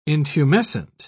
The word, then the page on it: intumescent: British English